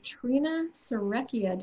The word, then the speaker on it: Trina Serrecchia: I am your host, and joining me today is another new friend of mine, Trina Serecchia. Did I say that correctly? – You did.